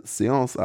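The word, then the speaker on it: Séance: Auf dem Friedhof vom Anfang halten unterdessen vier Teenies eine Seance ab. Spricht man das so aus? Ja.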